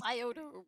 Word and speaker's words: Scioto: Did I say that right? – Scioto River. – Scioto? – Scioto. – It's silent? – Yep, silent.